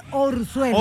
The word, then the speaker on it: orzuelo: ¿Y por qué el Urzuelo después? – ¿Quién me da cuenta? Ven, mira. – ¿Cómo se dice, Urzuelo o Orzuelo? – No, es Orzuelo.